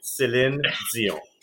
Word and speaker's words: Céline Dion: I know, but I can really say well, and I'll say it once and only once is Céline Dion, because I know y'all Americans don't say it properly, and that's how you say it, Céline Dion.